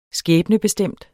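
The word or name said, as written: SKÆBNEBESTEMT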